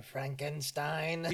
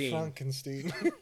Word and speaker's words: Frankenstein: He's like, Dr. Frankenstein. – Yeah. Yeah. – It's pronounced Frankenstein.